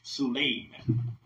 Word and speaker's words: Suleiman: Solomon in the Arabic language is pronounced Suleiman. In Hebrew, Solomon.